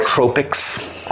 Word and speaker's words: nootropics: They make something that is spelled N-O-O-T-R-O-P-I-C-S. I think it's pronounced. Together, I think that we think it is pronounced nootropics. Nootropics